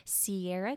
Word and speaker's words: Ciara: But you can find me at Sierra Castro. My name is spelled C-I-A-R-A Castro with an underscore at the end.